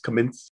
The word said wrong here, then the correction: Khmelnytsky: Khmelnytsky, that's yeah. – Khmelnytsky, yeah.